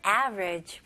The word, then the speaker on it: average: 이 단어는 average 이렇게 세 음절로도 물론 표현할 수 있겠지만 원어민들은 주로 이음절로 합니다. 그래서 여기 보시면 a, v, e, r 있잖아요. 그래서 e를 그냥 생략을 해서 a 첫 음절, 그 다음에 그냥 두 번째 음절은 ridge, ridge. 오케이? 다리 할 때, bridge 이잖아요. 여기서는 그냥 V 발음으로 average, average. 오케이? 여기서도 강세가 첫 음절에 들어갑니다.